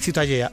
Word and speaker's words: Ciutadella: Ciutadella.